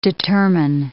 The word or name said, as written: determine